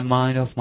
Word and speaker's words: mind: It takes my mind off my work, 能把我的注意力呢，从工作上转移开来。这地方一定注意这个 mind 的读音，然后 mind 和后面的 off, off, 一定要连读起来。